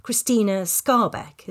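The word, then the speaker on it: Krystyna Skarbek: So one of your books, The Spy Who Loved, is about Christine Granville, otherwise known as Christina Scarbeck. Is that correct? – Very well pronounced, yes.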